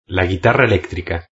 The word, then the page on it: la guitarra electrica: European Spanish